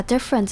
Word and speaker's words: different: 最后的这个小句里面有很多跟 different 相关的字，那我们就来讲一下 different 这个字到底该怎么念。那 different 这边在句子中其实第一个 e 的声音不会念得很明显，而是念成像 different，直接念成 f-r-e-n-t 的感觉， front 的感觉。